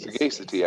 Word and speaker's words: sagacity: I think sagacity. I imagine be sagacious. – I think so. Let's don't. – Sagacity. Check. Maybe sagacity. – Sagacity. Yeah, it's a short A.